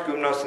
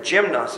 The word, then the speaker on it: gymnos: That's funny, the word here for naked, it's gymnos, or it's pronounced gymnos in the Greek, but we would pronounce it gymnos.